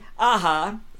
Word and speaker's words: Aja: Over the break, Elias corrected my pronunciation. David Aja is the correct pronunciation of the artist's name, and he seems like he's more interested in you pronouncing his first name correctly, which I've never done, and I will try.